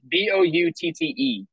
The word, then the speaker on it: Boutte: You know, the receiver that they have, is it Boutte or Booty? I don't even know how to pronounce it. It's Boutte.